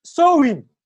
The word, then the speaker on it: Samhain: The actual name of Halloween is the pronoun sowin, sowin. The actual name is Samhain. I don't know how to pronounce it, but they said this is how they pronounce it, sowin.